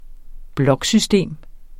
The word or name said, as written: bloksystem